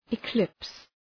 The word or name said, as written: eclipse